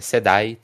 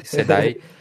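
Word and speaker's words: CEDAE: é SEDAI, tá? Falei errado, SEDAI.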